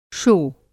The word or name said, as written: शो